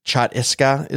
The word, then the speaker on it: Chhattisgarh: It's Chhattisgarh is the way you pronounce it.